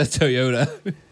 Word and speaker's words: Toyota: I just heard the lack of syllable. Like, you kind of rolled it into one. – Toyota. – I've gotten lazy. Toyota.